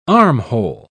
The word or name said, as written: armhole